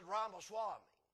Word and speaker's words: Ramaswamy: And he said, how do you say that name? I said, Ramaswamy. I said, you know, I sort of like that old song, Way Down Upon the Swanee River. That's how I remember it.